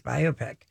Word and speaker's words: biopic: He always gently correct you. It's biopic. – Biopic. – Biopic. And you go, biopic. It looks like biopic. – It does. – Well, that is how the bloody British say it.